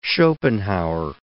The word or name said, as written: Schopenhauer